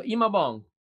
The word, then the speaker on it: Imaobong: The O is silent. – Okay. So Imaobong.